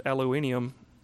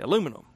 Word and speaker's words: aluminium: So he actually had to show me in the store what he was looking for, because I didn't know that that's how you pronounced aluminium anywhere other than South Carolina. We say aluminum.